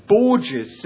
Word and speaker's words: Borges: I asked her how to pronounce her name after this poem, and she said it's Borges. Sarah Borges rhymes with gorgeous.